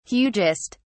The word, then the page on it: hugest: British English